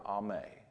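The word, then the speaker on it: ame (אם): In fact, the biblical word that is translated mother is the Hebrew word ame, A-M-E, it's pronounced ame, and it literally means the bond of the family.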